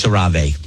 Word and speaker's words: CeraVe: It's pronounced CeraVe. It's CeraVe.